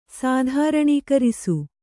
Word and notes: ಸಾಧಾರಣೀಕರಿಸು: kannada; sādhāraṇikarisu

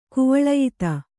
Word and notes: ಕುವಳಯಿತ: kannada

